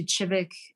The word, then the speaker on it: Kivik: And we went to Kivik, which is spelled with a K, like K-I-V-I-K.